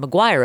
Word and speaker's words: Maguire: You can also follow me at Erin Maguire Official. That's M-A-G-U-I-R-E. God damn it. Spelled like Toby Maguire.